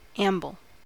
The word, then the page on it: amble: American English